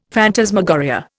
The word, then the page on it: phantasmagoria: Indian